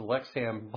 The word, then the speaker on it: Lexham: I always want to give an extra syllable there. The Lexham Bible Dictionary.